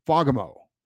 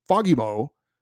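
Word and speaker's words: Fagemo: I'm certain because I have a friend from Sweden who told me how to pronounce it, that it's pronounced Fagamo, with the accent on the first syllable. The A is pronounced like an O in the words jolly and folly.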